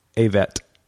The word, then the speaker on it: Avett: This is the Avnet Brothers. – Avett. – How do you say it? – A-V-E-T-T-E.